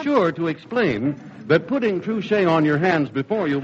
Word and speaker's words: Trushay: Spell it T-R-U-S-H-A-Y, Truché.